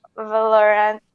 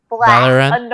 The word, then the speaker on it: Valorant: You know, there's the incident with Mika Daime, who potentially a lot of people were harassing because they looked at her playing Valorant. I'm not pronouncing things right. – Valorant.